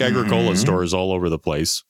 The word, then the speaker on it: Agricola: Number 9 is Agricola. – That's not how you pronounce it. It's Agricola.